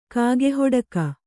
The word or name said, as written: ಕಾಗೆಹೊಡಕ